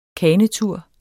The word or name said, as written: kanetur